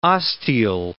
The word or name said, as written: osteal